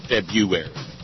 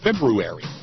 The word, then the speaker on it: February: February is a hard word to say. Most people just punt and say February, but there's an R in there, damn it. I'm going to try to pronounce it, February.